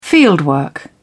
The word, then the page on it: fieldwork: British English